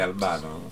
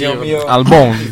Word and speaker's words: Albon: Sono bene Albano. – Sì, Albon. – Lo preferiamo. – Albano, preferiamo Albon. La pronuncia Albon. – Francese.